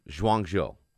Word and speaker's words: Guangzhou: Guangzhou. I think that's pronounced.